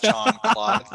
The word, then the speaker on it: Jean-Claude: It's actually pronounced Jean-Claude, Steve. Yeah, that's Jean-Claude. Okay, move along. – How does J-E-A-N say John?